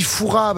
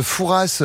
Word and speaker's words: Fouras: On dit Foura, parce que les gens disent Fouras en regardant Fort Boyard. C'est Foura. – Bah, parce qu'il y a un S et ils le prononcent. – Eh bah, mais on le prononce pas, voilà. On dit pas tu verras.